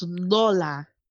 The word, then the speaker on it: Lola: It's pronounced Lola. There's like an accent, but I like that.